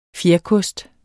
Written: fjerkost

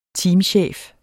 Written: teamchef

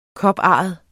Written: koparret

